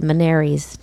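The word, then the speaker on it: Ménière's: and my diagnosis is bilateral Meniere's disease. We think that's how it's said, Meniere's disease. – That's fine.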